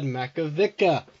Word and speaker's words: Makovicka: Welcome to the show, Todd Makovicka.